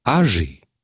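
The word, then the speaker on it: Aggée: A comme âgé.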